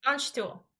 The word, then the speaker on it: Landstuhl: It's Landstuhl. – Landstuhl.